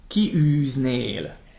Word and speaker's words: kiűznél: So the E is actually a separate syllable. It's not a glide like we would do. We would tend to go kiűznél. – Mm-hmm. No, ki, ki. I, ü, I, üznél.